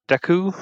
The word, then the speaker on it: Deku: But yeah, it was super solid, and there were some chuckles, and I like the main character, Deku.